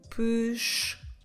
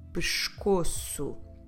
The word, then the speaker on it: pescoço: If I want to say we have pain in our neck, the word for neck in Portuguese is the word pescoço. Pescoço. I know this is not an easy one to remember. Como se escreve pescoço? P E S C, O, C de cedilha, O, pescoço, pescoço.